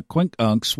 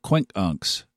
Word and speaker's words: quincunx: and also in a quincunx with Mercury. Did you know that's how you're supposed to pronounce it? I learned that from Steve Forrest, Endless Sky book, quincunx, quincunx, not quincunx, quincunx.